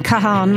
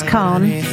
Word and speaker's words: Kahan: I call him Kahan. My daughter says Khan. One of us must be right. It's spelt K-A-H-A-N, so that's how I say it.